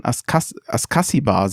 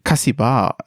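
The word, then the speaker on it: Ascacíbar: Und wenn man ihn jetzt deutsch ausspricht, müsste man Askasibar sagen. Er selber hat sich aber mal bei Hertha TV mit Kassibar ausgesprochen, wenn ich das richtig verstanden habe.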